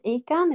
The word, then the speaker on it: Ican: My name is Ican. It's spelled ICAN. I'm a 42 year old woman from Sweden, and I was diagnosed late with ADHD and autism type 1.